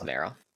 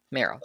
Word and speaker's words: Merrill: Is it Merrill? Okay. Just like the Pokemon. – No, it's Merrill. – Merrill? – Merrill.